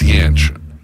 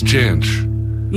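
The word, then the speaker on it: Jansch: It's Janch. – Janch? – Yeah. – Janch. – Yeah. But it's Janch.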